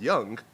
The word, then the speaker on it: Young: So I've been pronouncing my own name wrong for, like, my entire life, so... Mm. I still call myself Young, and okay.